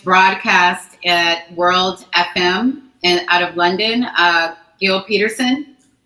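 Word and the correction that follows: Gilles Peterson: Giles Peterson, yes. – Giles, thank you. I always say that wrong.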